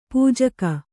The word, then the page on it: ಪೂಜೆಗೈ: Kannada